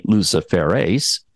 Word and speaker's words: luciferase: I hope I'm saying that right. I'll spell it for you. L-U-C-I-F-E-R-A-S-E. Luciferase. – Sounds right to me.